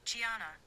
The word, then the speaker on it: Gianna: Gianna.